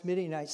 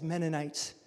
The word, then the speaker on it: Midianites: So we're in Judges 7, and we're reading about Gideon defeating the Midianites, and the young man who gets to pronounce Midianites says Mennonites. Mennonites.